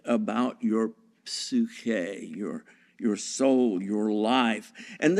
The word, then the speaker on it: psyche: a transliteration of the Greek word psyche. Psyche, or psyche, as we'd like to pronounce it in English, psyche, is the Greek word for, the regular translation would be soul.